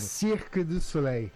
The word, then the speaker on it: Cirque du Soleil: Cirque du Soleil.